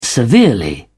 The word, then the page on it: severely: British English